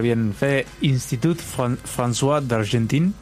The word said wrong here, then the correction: Institut Français d'Argentine: ¿Lo dije bien? – Institut Français d'Argentine. – No lo dije.